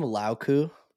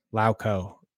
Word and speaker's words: Lauko: I love how you call him Lauku. – I can't fucking pronounce last names. How do you say it? – Lauko.